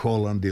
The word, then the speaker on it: Haaland: Još možda malo o Golgeterima, pomenuli smo neke istaknute napadače kojima možda ide karijera naniže, ali recimo evo razigrao se Holland ili Haaland, kako se različito izgovario, boga mi Harry Kane igra baš sa puno želje.